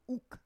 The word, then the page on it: uk: Roon